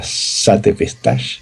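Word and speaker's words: Sate Pestage: Hay uno de ellos que se llama Sate Pestash. No, no sé cómo se pronuncia en inglés. – ¿Sacapestas? Jolines. – Sate Pestaje, se escribe.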